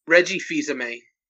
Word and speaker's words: Reggie Fils-Aimé: Reggie Fils-Aimé.